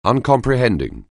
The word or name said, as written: uncomprehending